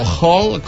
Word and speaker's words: Hull: Avui et parlo, tu de dia, de l'heroi del Hull, o Hull, com hauríem de pronunciar.